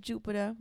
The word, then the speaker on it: Jupitr: My name is Jupitr. You can find me on all social media sites at She Is Jupitr. Jupitr is not spelled with an E. It's J-U-P-I-T-R.